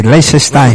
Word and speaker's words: Liechtenstein: Se pronuncia así, Liechtenstein.